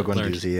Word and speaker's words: Guendouzi: Yeah, Matteo Guendouzi.